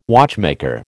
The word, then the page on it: watchmaker: American English